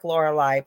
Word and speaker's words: Lorilei: It is actually called the Lorelei's, but it's spelt like Lorelei, but it's my name is said Lorelei.